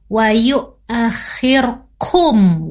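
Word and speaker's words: Wayu akhir kum (وَيُؤَخِّرْكُمْ): Ya, boleh kita lihat, dibacanya bukan hum tapi kum. Wayu akhir kum, wayu akhir kum, bukan hum tapi kum.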